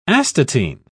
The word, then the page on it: astatine: British English